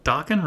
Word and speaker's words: Dokken: Dude, Dokken rhymes with rockin'.